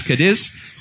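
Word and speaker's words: Cádiz: And apparently he made a trip to Cadiz, or Cadi, I'm told by the locals is how it's pronounced. But I guess in English we know it as Cadiz.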